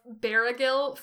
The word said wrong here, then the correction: Bergil: Not even Barragil. Bergil. It is two syllables. It's just Bergil.